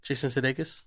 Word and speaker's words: Jason Sudeikis: Jason Sudeikis.